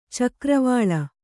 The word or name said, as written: ಚಕ್ರವಾಲ